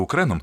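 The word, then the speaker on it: Ukraine: Він мене назвав Укреном, бо йому вподобалась назва мого краю. Україна по-французькому вимовляється Укрен або Юкрейн.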